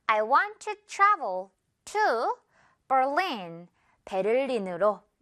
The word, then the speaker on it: Berlin: 자, 그런데 영어 발음은요, Berlin, 이렇게 눈에 보이죠? 이대로 해주면 돼요. Berlin, Berlin, okay? Berlin, not Berlin, Berlin, 이건 이제 잊어요.